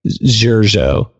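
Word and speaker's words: Xurxo: Yeah, so our buddy, um, he's from Spain, and uh, he tells us that none of us pronounce his name right, but it's uh, it's like Xurjo. It's X U R X O, oh, I think.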